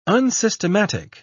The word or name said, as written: unsystematic